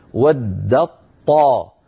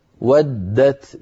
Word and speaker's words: ودت: لأن التاء والطاء حرفان متجانسان يعني من مخرج واحد، فأدغمت التاء في الطاء، ويكون الإدغام بتحويل التاء إلى طاء ثم إدغام الطاء في الطاء، فيصير النطق بطاء مشددة لا يبقى للتاء أثر، هكذا ود الطاء ود الطائفة. هذا أيها الإخوة في حالة الوصل، فإن وقفنا نعود يعود الأمر إلى الأصل فنقول ودت ودت بتاء ساكنة.